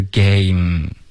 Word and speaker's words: game: OK，比如 game 这个字游戏，它的结尾呢只是一个没了，没有一个么。 OK，虽然它的就是结尾有一个 e 的这个英文，可是它是不发音的，所以它的发音是 game，有一个就够了。